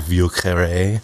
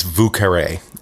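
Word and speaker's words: Vieux Carré: This cocktail is a New Orleans classic, and although the name is French, the pronunciation is pure Creole. Oh. The French pronunciation would be more like Vu Carré, but it's Vu Carré.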